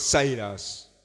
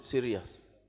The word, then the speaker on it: Sirius: Emunyenye ichiwa Sirius oba Cyrus. – Yeah, they pronounce it Sirius.